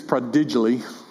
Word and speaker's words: prodigally: I don't think it's prodigally. Maybe. No, the emphasis is on the first syllable.